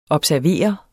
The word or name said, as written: observere